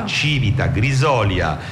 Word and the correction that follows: Grisolia: Santa Severina, Grisolia, pardon, brava, mi ha messo l'accento, e Bova.